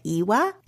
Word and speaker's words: Iwa: That's the brand, if you didn't know, Born, B-O-R-N. And the style is called, I think they're pronouncing it Iwa. It's I-W-A.